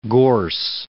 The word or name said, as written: gorse